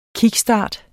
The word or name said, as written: KICKSTART